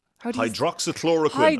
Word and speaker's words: hydroxychloroquine: I can never say this word hydroxychloroquine.